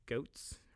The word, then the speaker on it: Goetz: Death by Suburb, and it's by David Goetz, or Getz, actually, I guess it's pronounced Getz.